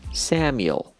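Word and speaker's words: Samuel: اما تا قبل از اینکه من بخوام این رو تلفظ بکنم اجازه بدین سپل بکنم، S-A-M-U-E-L S-A-M-U-E-L سامیل، سامیل. ببینیم ما در فارسی میگیم ساموئل، حالا انگلیسی سام یل، سامیل، سامیل، سامیل.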